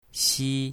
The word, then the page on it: xi1: mandarin